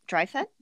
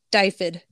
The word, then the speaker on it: Dyfed: Paul's pen Anuvin, who was the king of Dryfed? – Dyfed. – Dyfed. Okay. – Or, so he, sorry, Dyfed.